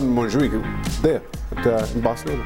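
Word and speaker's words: Montjuïc: Montjuic. – Montjuic.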